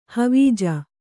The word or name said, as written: ಹವೀಜ